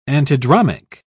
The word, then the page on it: antidromic: British English